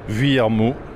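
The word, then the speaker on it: Vuillermoz: They lost Alexis Viermo. – Well done. Excellent pronunciation.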